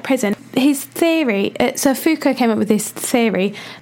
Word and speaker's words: Foucault: We ought to spell out Foucault. It's Foucault. – Yeah. Wait, how are you... Am I saying it right then? – Yeah, Foucault. It's French.